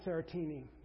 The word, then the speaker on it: serotiny: I pronounce it Serotiny. Or Serotiny. Actually, it's Serotiny. I know some of you hillbillies probably say Serotiny, but the sophisticated folks say Serotiny.